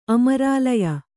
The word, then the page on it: ಅಮರಾಲಯ: Kannada